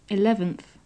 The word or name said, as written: eleventh